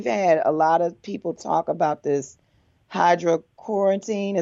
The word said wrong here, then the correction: hydroxychloroquine: Is that how you pronounce it? I want to make sure I'm saying it right. – Hydroxychloroquine. – Hydroxychloroquine. – Yes.